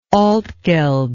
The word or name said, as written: Altgeld